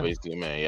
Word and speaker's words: Azume: So I guess we can move over to, like I said, the Wanderers, is it of Azume? Is how you pronounce that? – Wanderers of Azume, yep.